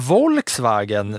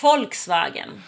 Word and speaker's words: Volkswagen: Tänk på uttalet här va? Ja, förlåt. Jag är bara bra på, itali jag, jag, på italienskt uttal. Det här är ju tyskt, det får ju du ta. – Ja, Volkswagen. – Ah, det är Volkswagen.